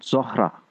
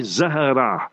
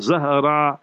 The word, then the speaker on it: Zahra: So you must say Zahra, Z-A-H-R-A-A. So then it will be like Fatima Zahra. So Zahra, Z-A-H-R-A-A.